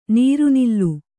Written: ನೀರ್ನೆಲ

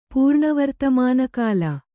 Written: ಪೂರ್ಣವರ್ತಮಾನಕಾಲ

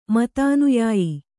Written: ಮತಾನುಯಾಯಿ

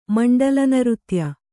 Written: ಮಂಡಲನೃತ್ಯ

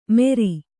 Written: ಮೆರಿ

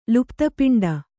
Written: ಲುಪ್ತಪಿಂಡ

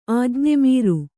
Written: ಆಜ್ಞೆಮೀರು

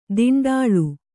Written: ದಿಂಡಾಳು

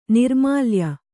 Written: ನೈರ್ಮಲ್ಯ

